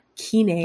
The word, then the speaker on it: Kenai: Kenai.